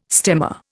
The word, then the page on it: stemma: Indian